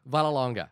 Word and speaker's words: Vallelonga: Vallelonga.